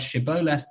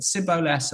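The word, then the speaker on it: Shibboleth: And the Psukim talk about how Ephraim couldn't say the word Shiboles, they said Siboles, and therefore a way of finding out who were the Ephraim people was to test them, test how they could pronounce it.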